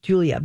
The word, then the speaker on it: Guila: Now, I would not have realized that it, if somebody's looking it up, that it's pronounced Julia because it is spelled G U I L A.